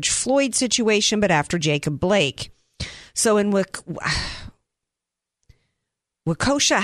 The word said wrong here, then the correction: Kenosha: how do you say it? Kenosha, Wisconsin. I can never get the name of that right.